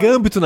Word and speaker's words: Gambito: Eu não sei, o que, que é Cambito? Todo mundo quer é falar. É Gambito, na verdade, a, pronúncia.